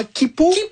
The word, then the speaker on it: Quipu: Et si vous pensiez que c'était déjà vertigineux d'imaginer l'Aniakea, attendez qu'on vous parle de comment Kipu, oui. – Ça s'écrit Q-U-I-P-U.